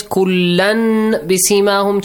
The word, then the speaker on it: كُلًّا بِسِيمَاهُمْ: So you change the hidden noon sound, which you should not pronounce, to a hidden meme sound, which you should pronounce. – Mm. So it says kullan bisimahum changes to a hidden meme sound.